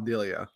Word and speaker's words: Delia: I still laugh at the fact that on the hot, like, how the pronunciation guide for Colin Delia, it's literally Colin Delia.